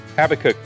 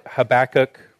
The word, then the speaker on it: Habakkuk: So we're going to be in the book of Habakkuk, Habakkuk chapter 1. Don't turn your pages too fast, you'll go right past it. Or Habakkuk, you can pronounce it either way.